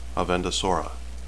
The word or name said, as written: avendesora